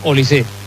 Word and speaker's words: Olise: No lleva acento, pero se pronuncia Olise.